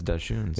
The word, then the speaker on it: dachshunds: Deschunes. – Dachshunds. – Is that? I thought it was dachshunds. – It's dachshunds. – Oh, okay. That's dachshunds? – Yes. – I swear. – That's how you pronounce it.